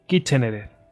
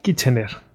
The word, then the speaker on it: Kitchener: Contra los sudaneses en el equipo de Kitchener, lo digo mal, Kitchener,